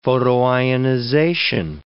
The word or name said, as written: photoionization